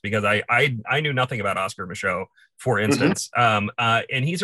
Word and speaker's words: Micheaux: I want to talk about, please correct me if I'm pronouncing his name incorrectly, but Oscar Michaud, is that? – You're correct.